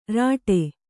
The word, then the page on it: ರೀಠೆ: kannada